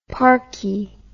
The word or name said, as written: parkie